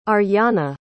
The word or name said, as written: Aryanna